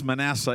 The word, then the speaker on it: Manasseh: It's not pronounced Manasseh, it's Manasseh.